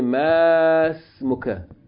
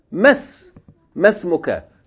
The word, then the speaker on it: ma ismuka: Okay, the other rule is that whenever you have a long vowel preceding Hamzatul wasl, you don't pronounce it long, you pronounce it short. You don't say masmuka, you say mas, as though it's just yarhamukallah, as though it's just meem fatta. Mas, masmuka.